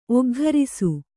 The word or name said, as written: ಒಗ್ಘರಿಸು